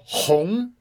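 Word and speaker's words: Hong (红): Okay, so the first movie we have is from Chad Wrestler on Make a Movie for Hong, which means red. It's got the uh, components of silk and the character for work, and it's pronounced H O N G second tone.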